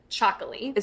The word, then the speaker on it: Txakoli: It's spelled really interesting T X A K O L I, and you pronounce it Chocoli.